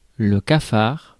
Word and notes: hump: hʌ.mp

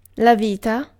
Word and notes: life: /laɪf/

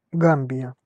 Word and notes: Gambia: [ˈɡæm.bi.ə]

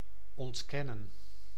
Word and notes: ontkennen: [ɔntˈkɛ.nən]

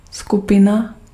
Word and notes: skupina: /ˈsku.pɪ.na/

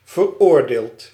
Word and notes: flétri: /fle.tʁi/

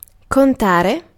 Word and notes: contare: /kon.ˈta.re/

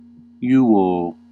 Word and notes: iuvō: /ˈju.woː/; Classical

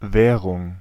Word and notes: currency: /ˈkɝ.ən.si/; /ˈkɜːɹ.ən.si/

